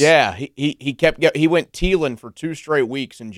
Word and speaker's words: Thielen: Yeah, but I think Joe and I did the right thing there because that's how you say his name. It's Thielen.